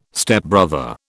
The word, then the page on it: Stepbrother: British English